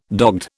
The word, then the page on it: dogged: British English